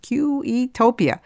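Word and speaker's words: Queuetopia: It was a word invented by Winston Churchill, and I actually had to go online to find out how to pronounce it. Q-E-topia.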